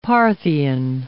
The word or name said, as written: Parthian